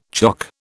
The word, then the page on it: chock: British English